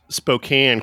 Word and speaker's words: Spokane: Well, I want to give you kudos because you at least pronounce Spokane correctly for that song.